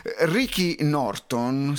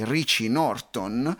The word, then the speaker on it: Richie Norton: Ricky Norton, sì, penso che si, si pronunci così il suo nome, Richie, Richie Norton, uno scrittore consulente americano, ha espresso un concetto molto interessante sulla zona di comfort.